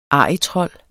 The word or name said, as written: arrigtrold